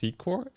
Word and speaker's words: Secor: And his partner is Detective Bayless, who's played by Kyle, I believe it's pronounced Secor, S-E-C-O-R.